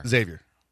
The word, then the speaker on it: Xavier: Xavier. – Xavier. – That's correct. – Yeah. Z. – That is the biggest for the people that live in the Midwest. That they pounce on you. Like if Xavier was playing here and we had a regional, for every Texan that said Xavier's playing tonight, Xavier.